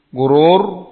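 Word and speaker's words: Gurur (غرور): Gurur. Ini barakallahu fikum. Rasukun. Sebelumnya dhamma. Cara bacanya dengan tafkhim. Tafkhim itu tebal. Gurur. Kalau gurur. Ini tipis. Gurur. Tebal.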